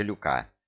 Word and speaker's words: Люка: Нет, лучше Люка. Только не путай. Ударение на последнем слоге.